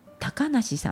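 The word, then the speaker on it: 小鳥遊: ね、小鳥が遊ぶと書いて小さい鳥が遊ぶという 3 つの漢字で高梨さんって読むそうなんですね。なんでって感じですけど、これは鷹がいないから小鳥が自由に安心して遊べるっていうところから高梨さんと呼ぶそうです。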